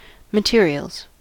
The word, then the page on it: materials: American English